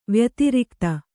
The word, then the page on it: ವ್ಯತಿರಿಕ್ತ: vyatirikta